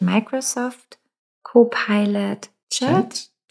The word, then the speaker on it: Microsoft Copilot Chat: Du hast eben gesagt, als du über, ich hoffe, ich sage es jetzt richtig, Microsoft Copilot Chat. Habe ich es richtig gesagt? – Ja, ja.